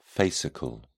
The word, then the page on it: fascicle: British English